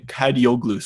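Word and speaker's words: Kadıoğlu: You think of Ferdi Kagli. I can't. His name is the proper pronunciation of his name. Kadioglu. Sorry, Ferdi Kadioglu.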